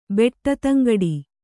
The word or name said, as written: ಬೆಟ್ಟತಂಗಡಿ